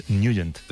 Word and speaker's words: Nugent: Hombre, siempre lo hemos llamado en el barrio Tednagent o Tednagent. – Claro, ¿no? Yo era de Tednagent. – Pero la pronunciación correcta es Nugent.